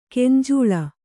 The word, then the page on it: ಗಂಜಳ: gañjaḷa